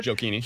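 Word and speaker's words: Gioacchini: Uh, I know our, our guy, Gio, Gio, Giochini that, is that how you pronounced it? Jokini.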